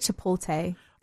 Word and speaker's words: Chipotle: My mom calls it Chipotle. – Oh, Chipotle.